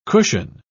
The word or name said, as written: cushion